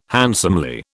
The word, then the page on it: handsomely: British English